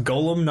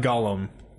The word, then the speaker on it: Golem: It's pronounced Golem, not Gollum. I fucking hate that. – Yeah, Gollum is the dude from Lord of the Rings.